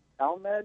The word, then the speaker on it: Talmud: and there was a blog about the Talmud. Is that how you pronounce it? – Mm-hmm.